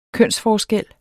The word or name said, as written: kønsforskel